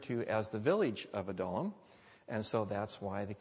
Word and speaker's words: Adullam: Now, the word Adullam is a word, as you might expect with a lot of biblical words, that some people pronounce differently. You'll hear some people pronounce it Adullam, or you'll hear some people pronounce it Adullam. But the best I could find in all the research I did was that the consensus is that it's the cave of Adullam.